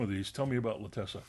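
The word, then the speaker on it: L'attesa: The new stuff this week is Piero Messina's La Lessa with Juliette Binoche. – La Tessa.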